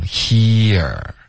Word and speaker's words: here: hearing 对，这里大家要注意，就是开头的这个我写的音标会有一点不一样，我会写一个比较饱满的 e 的音 he OK，然后 e 后面呢要滑到一个饱满的 er，所以你会听到一个滑动 here almost like two syllables，有点像两个音节了。